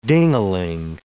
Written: ding-a-ling

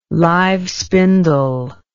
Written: live spindle

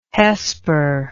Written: hesper

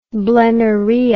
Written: blennorrhea

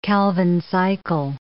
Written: Calvin cycle

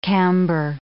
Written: camber

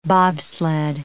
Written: bobsled

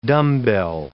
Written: dumbbell

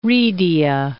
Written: redia

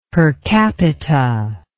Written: per capita

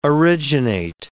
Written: originate

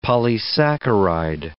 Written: polysaccharide

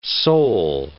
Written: sole

